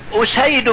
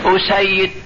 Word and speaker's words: أسيد: هنا لا، الكتابة واحدة، يمكن تقرأها يمين يمكن تقرأها يسار، بمعنى يقولون مثلا أسيد بن حضير، أسيد، أسيد، الفرق بينهم الهمزة والسين والياء والدال هي هي، لكن النطق جاء بتشديد الياء مصغر وجاء بتخفيفها مخفف، يبقى فيه فرق في النطق، هو الذي فرق بين الشخصين.